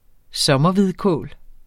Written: sommerhvidkål

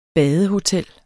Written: badehotel

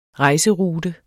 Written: rejserute

